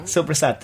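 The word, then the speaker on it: soppressata: But how do you pronounce it? How's the actual Italian name? – Supersata.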